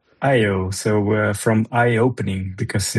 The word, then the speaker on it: EYEO: So it's eye opening IO. That's where it's coming from. Yeah. – So it's an English joke. – It's an English gimmick. Yeah. – It's spelled EYEO for people listening to this.